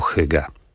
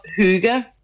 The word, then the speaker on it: hygge: Po duńsku będzie to hygge. – Hygge. – Hygge. – Hygge. – Czyli y, które wymawiamy bardziej jak niemieckie u umlaut. Nie słyszymy tego podwójnego G. No i E tak bardziej wygłosowo.